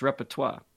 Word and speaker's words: repertoire: I mean, it's spelled repetit, but I knew it's repertoire.